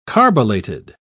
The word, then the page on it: carbolated: British English